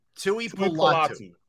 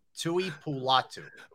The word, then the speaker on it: Tui Pulotu: Tui Pulutu. I think that's what his name is. – Pulatu. Tui Pulatu. Yeah, T-U-I-P-U-L-O-T-U. Tui Pulatu.